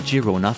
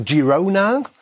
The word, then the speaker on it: Girona: Auch die Engländer sagen eher so Girona. – Ja, aber es ist, also der Spanier sagt Girona, so wie Gijon, äh, Girona, G, und die Katalanen sagen Girona.